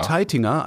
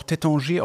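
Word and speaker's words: Taittinger: sowohl Teitinger als auch Tétanger aussprechen kann und nicht falsch liegt.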